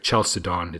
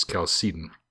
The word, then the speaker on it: Chalcedon: It's a hard C on the word chalcedon. Even though it looks like chalcedon, it's chalcedon.